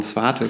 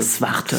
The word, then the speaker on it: Zwarte: Wie wird das so ausgesprochen? Zwarte. Zwarte. Zwarte Moorsee.